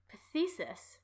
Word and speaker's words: phthisis: Phthisis.